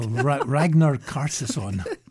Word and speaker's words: Ragnar Kjartansson: There's a double pass to the National Gallery of Victoria's Ranyer Kjartansson. – Pronunciation's right next to the word. – Oh, Ragnar Kjartansson.